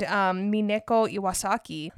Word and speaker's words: Mineko Iwasaki: But most notably, he did interview an actual geisha named Mineko Iwasaki. – Nice pronunciation.